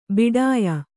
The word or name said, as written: ಭಿಡಿಯ